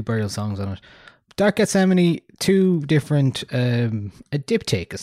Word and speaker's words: Gethsemane: Ah, um, Dark Gethsemane, I believe it Gethsemane, is. Gethsemane. – Um, oh, like Yosemite. Okay.